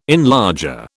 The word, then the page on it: enlarger: British English